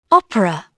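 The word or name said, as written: operation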